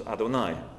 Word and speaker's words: Adonai: we're immediately struck by the singular use of the tetragrammaton, Yud-Heh-Vav-Heh, which we pronounce in Judaism as Adonai, Lord.